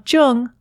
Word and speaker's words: Jung: You can also listen to this episode on your Amazon Echo device, simply by saying Alexa, play Speaking of Jung on Apple Podcasts. Just be sure to pronounce Jung with a hard J.